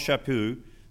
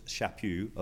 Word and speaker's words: Chaput: Well, Archbishop Charles Chaput, that is his pronunciation, is hard. It looks like Chaput. It's Chaput of Philadelphia says this.